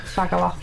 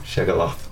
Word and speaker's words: Shaggaloth: I've only just realised that the name you is Shagaloff. I put that into a random orc name as well, and that it's spelled S H A G G A L O T H.